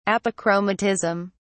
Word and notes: apochromatism: British English

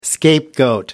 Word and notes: scapegoat: American English

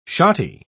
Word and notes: shotty: British English

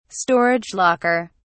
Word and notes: storage locker: British English

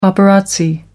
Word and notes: paparazzi: American English

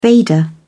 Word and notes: veda: British English

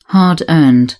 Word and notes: hard earned: British English

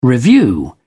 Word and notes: revue: British English

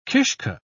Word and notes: kishke: British English

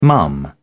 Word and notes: mum: British English

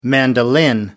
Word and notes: mandolin: American English

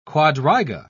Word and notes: quadriga: British English

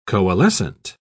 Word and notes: coalescent: British English

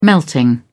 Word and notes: melting: British English

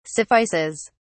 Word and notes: suffices: British English

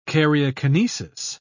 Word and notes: karyokinesis: British English

